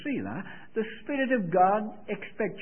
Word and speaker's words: Selah: It's spelled S-E-L-A-H. Selah.